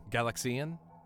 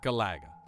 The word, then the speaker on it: Galaga: Galaxian? – Or Gallagher? – Galaga.